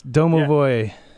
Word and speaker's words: Domovoy: How do you pronounce this? Domovoy? – That's Domovoy. Yeah. Yep. It's a Russian house ghost, which is what that title's about.